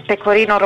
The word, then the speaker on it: Pecorino: And for the cheeses, Taleggio, Provolone, Pecorino Romano e Toscano, Parmigiano, Mozzarella di Bufala, Grana Padano, Gorgonzola, Fontina and Asiago.